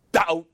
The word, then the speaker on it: doubt: Doubt. D-O-U-B-T. B is silent. Doubt.